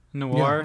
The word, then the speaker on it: noir: Am I saying this right? Noir. – Noir. Yeah, noir. noir.